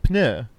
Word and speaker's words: pneu: But you pronounce the P. – What? – Pneu.